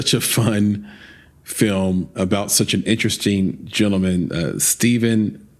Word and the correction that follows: Stefan: Pokorny. – Stefan. – I'm sorry. I actually have it spelled right in front of me and I said it wrong anyway.